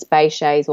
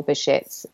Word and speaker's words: Behçet's: And yes, I was diagnosed with, there's a few pronunciations, Behçet's or Behçet's.